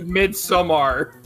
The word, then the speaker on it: Midsommar: Then we can make a porn version of Midsommar.